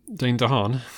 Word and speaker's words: Dane DeHaan: Any relation to Dane Lehane? – Dane DeHaan.